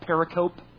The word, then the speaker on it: pericope: P-E-R-I-C-O-P-E. It looks like pericope, but it's pronounced pericope.